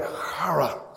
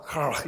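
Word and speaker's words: chara (χαρά): You know, but this Greek word, as you would read this, it almost sounds like chara, chara. You know, like you're clearing your throat a little bit.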